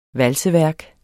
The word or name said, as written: valseværk